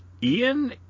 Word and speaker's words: Eoin: I think it's like Ian, E-O-I-N, Colfer, would add a sixth book after Douglas Adams' death, and this was entitled And Another Thing.